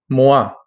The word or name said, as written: moi